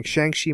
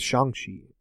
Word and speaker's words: Shang-Chi: I was saying Shang-Chi my whole life, but now it's Shang-Chi. Like that's, I think that's the more proper way to say it.